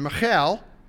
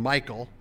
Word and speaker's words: Michal: we're going to pronounce that very Hebraically rather than Englishly, because some people don't like a girl named Michael. It's really not a problem with me, but we'll say Michal,